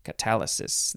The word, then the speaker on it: catalysis: It is catalysis. That's not how you say it. Catalysis. C-A-T-A-L-Y-S-I-S, catalysis, noun from 1836,